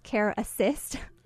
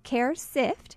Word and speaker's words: CareSift: dot uh, it's a difficult name to pronounce, CareSift. Some people call us Care Assist or CareFit, but it's CareSift